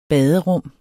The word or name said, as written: baderum